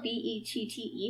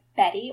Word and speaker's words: Bette: Her name is spelled Bette, which I guess can be pronounced as Betty or Bet. We're calling her Bet because Betty is, like, my grandmother's name, and I feel, and she's like yeah, she's a little too high class for that.